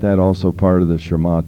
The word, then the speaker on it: Shema: But also isn't a part of Shema, and it's spelled S-H-E-M-A, but pronounced Shema.